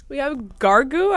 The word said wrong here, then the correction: Grogu: I think that's how you say it, and the Mandalorian. – Grogu.